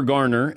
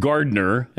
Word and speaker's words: Garner: And good luck with, Jennifer Garner. It's not Gardner. As you had her name wrong the last time you were on.